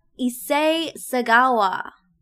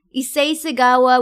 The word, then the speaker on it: Issei Sagawa: Um, but anyway, we're talking about Issei Sagawa.